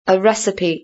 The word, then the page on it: a recipe: British English